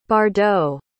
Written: bardot

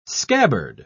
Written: scabbard

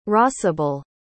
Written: rosabelle

